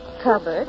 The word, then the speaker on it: cupboard: Oh, Mother Hubbard went to the cupboard. It rhymes with Hubbard.